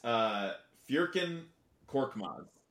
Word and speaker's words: Furkan Korkmaz: His name is Furkan Korkmaz.